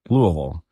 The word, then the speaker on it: Louisville: I honestly don't think I could pronounce it till about 5 or 10 years ago. Louisville. – Mm-hmm. – Right? – Yeah. – Okay. See, I finally got it.